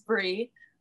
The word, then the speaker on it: Bri: My name is Bree. I always say my name is Bree like the cheese, but it's not, because it's spelled B-R-I without the E.